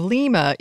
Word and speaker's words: lima: Its name does come from the Peruvian capital of Lima, even though perversely it is pronounced lima in English.